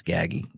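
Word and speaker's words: Gaggi: That's Gaggy, not Gigi.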